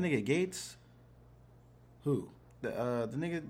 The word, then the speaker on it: Gaetz: I, I well, like, he's it's spelled weird, but it, it's Gaetz.